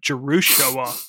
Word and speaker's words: Jerusha: It looks like Joshua, but Jerusha.